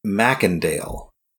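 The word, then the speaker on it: Macendale: After Leeds was murdered, the identity and methods of the Hobgoblin were adopted by Jason Philip Mercandale Jr. I think it's Mackendale. – Mackendale. – At least that's how they pronounce it on the 90s cartoon. – Mackendale. Yeah, I was putting an R in there for no reason.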